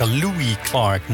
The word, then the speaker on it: Louis Clark: En dat was weer Lewis Clark, Louis Clark moet ik zeggen, want dat is de juiste uitspraak.